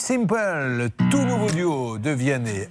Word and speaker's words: Vianney: Mais il faut faire attention, hein, parce que si vous dites Viana et Mickey, ils pourraient le prendre très mal. Donc il faut bien prononcer.